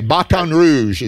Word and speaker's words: Baton Rouge: You're from Baton Rouge. Yeah. Did I say that right? – You sure did. – Okay, Baton Rouge, as I say.